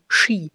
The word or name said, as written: Ski